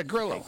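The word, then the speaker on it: Grillo: Did I say that right? – What? – Gorillo, I got right. – Yeah, Gorillo. Yeah.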